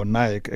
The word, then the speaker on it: Naik: Lee or oh, Nike actually. Why do I want to pronounce it differently, Lee? I, I just feel like when I say Nike, I feel like I'm a South African who's mispronouncing Nike.